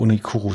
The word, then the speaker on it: Uniqlo: Ja, hier Uniqlo. – Ich glaube, Unikuru spricht man es aus, aber genau, das ist Fast Fashion.